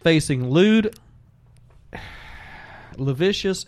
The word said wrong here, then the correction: lascivious: and lavicious battery charges. – Lascivious. – What did I say? – Lavicious. – That sounds a lot better. How do you say it? – Lascivious.